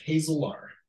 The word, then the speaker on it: Hazelaar: And our last name is Hazelaar.